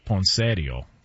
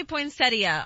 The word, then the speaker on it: poinsettia: Well, the in-laws would call it poinsettio. – Oh, gosh. – Okay. What up? You know what I'm saying? Can we get the proper pronunciation on this? – It does say poinsettia online.